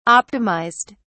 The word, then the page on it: optimized: British English